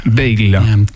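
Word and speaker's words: Bayle: On va commencer cette émission avec le spectacle Illusion perdue d'Honoré de Balzac, mis en scène pour l'occasion par Pauline, reprends-moi si je me trompe sur la prononciation, Belle Pauline Bale, ouais. Bale. tout petit ah, i. Euh, c'est un Y, B-A-Y-L-E.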